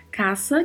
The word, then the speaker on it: casa: Caça, que é um substantivo com significado similar ao do português, e a atenção nossa aqui é quanto à pronúncia, porque o S não tem som de Z. Falamos caça.